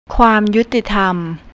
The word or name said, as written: khuamyuttitham